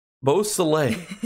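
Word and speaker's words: Beausoleil: How do you say his last name? Bo Soleil,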